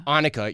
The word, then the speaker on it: Annika: My I think German, it's Annika. Annika, yes, it is Annika. That's a German pronunciation.